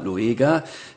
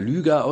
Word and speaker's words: Lueger: denken wir nur an den Bürgermeister Karl Lueger, der so gerne Lüger ausgesprochen wird, mit OE geschrieben, das war der geistige Ziehvater oder ungeistige Ziehvater von Adolf Hitler mit seinem unglaublichen Antisemitismus.